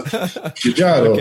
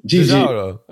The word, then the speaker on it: Giugiaro: So, Giugiaro. Okay, you gotta say it like that. – Giugiaro.